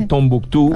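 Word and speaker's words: Tombouctou: En francés, se, dice Tombuctú. En inglés se dice Timbuktu.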